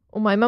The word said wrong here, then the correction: हुमायमा: हुमायमा यार. उमायमा नहीं, हुमायमा.